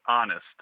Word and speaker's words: honest: Ethnos, E-T-H-N-O-S. – This one, I am at a loss. – Yeah, those silent H's are tricky. – I am stumped on this one. – It's a good adjective to describe as a way to describe someone. – Oh yes, honest.